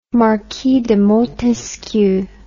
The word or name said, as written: marquis de montesquieu